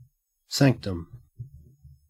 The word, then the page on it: sanctum: Australian English